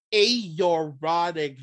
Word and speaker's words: aortic: Aortic valve.